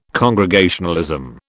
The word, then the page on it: Congregationalism: British English